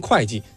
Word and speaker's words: 会稽: 这个会稽就跟现在会计这俩字是一样的，那叫会稽。就是说当时秦对于文吏的要求，就首先你能写字儿，第二个你能算东西。所以你看这个会稽现在我们念成会稽，但它本意其实都没变，可能当时也念会。